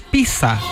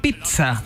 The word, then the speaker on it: pizza: Bueno, dice que la pronunciación más común en español es pizza, con una I. Más corta, ¿cierto? Pero en italiano se pronuncia pizza, con una I más marcada y un sonido más fuerte. Bueno, en realidad cualquiera de las dos se entiende, pero la versión italiana es la original, pizza.